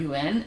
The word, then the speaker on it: Yuen: So my family's historical name is actually Yen or mm-hmm. Yuen, and so it's spelled Y-U-E-N.